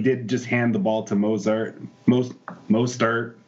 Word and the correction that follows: Mostert: Uh, sorry, I'm pronouncing his name wrong, but he's a beast. Mostert. Um, there you go.